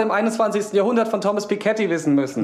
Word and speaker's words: Piketty: Piketty wird das ausgesprochen.